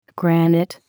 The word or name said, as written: granite